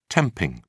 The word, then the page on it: temping: British English